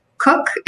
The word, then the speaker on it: Koch: And my first and last name, um, Rachel with an E, just an E L, and then my last name is tricky, even though it's pronounced Cook. It's K O C H.